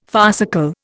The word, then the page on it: farcical: Indian